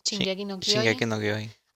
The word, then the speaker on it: Shingeki no Kyojin: Shingeki no Kyojin.